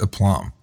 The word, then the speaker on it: aplomb: And I said, aplomb. And he goes, seriously? And I was like, yeah. And he goes, it's aplomb. It's pronounced aplomb. You really thought it was aplomb?